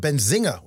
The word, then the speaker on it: Benzinga: I'm sorry, I didn't mean to cut you off, but you pronounced it great. Some people forget the N and go Benzinga, Benzinga, and I get annoyed.